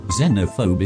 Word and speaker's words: Xenophobe: Now, I'm an American through and through, but I actually prefer the British pronunciation, Xenophobe, just because Z sounds so horribly horizontal to my musically trained ear. – Xenophobe is the correct pronunciation, so bully for you.